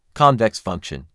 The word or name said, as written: Convex function